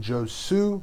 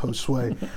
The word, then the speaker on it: Josue: If you are reading my name, you're probably saying Josue, but it's pronounced Josue.